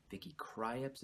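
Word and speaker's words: Vicky Krieps: And it also stars Daniel Day-Lewis, Vicky Kryeps is how I believe you pronounce her name, and Leslie Mansville.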